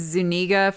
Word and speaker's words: Zuniga: Princess Vespa is played by Daphne, I think it's Zuniga, forgive me if I'm mispronouncing. – No, I think that's right, yeah.